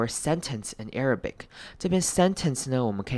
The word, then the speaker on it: Arabic: 那接下来我们要看到 Arabic 这个字也是比较难念的，阿拉伯文这个字它开头的 A-R 要念成 air 的声音，念成长母音 A， Arabic， Arabic，中间的 A 念成轻音，最后的 I 呢念成短母音 I，然后重音在第一个音节 Arabic， Arabic。